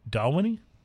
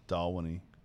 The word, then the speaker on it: Dalwhinnie: In fact, I think you may have mispronounced the name of the whiskey. – Well, I think Wiki Brian posted on here that it's, how did he say it? – Dalwhinnie. Dalwhinnie. We were calling it Dalwhinnie.